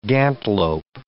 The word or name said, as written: gantlope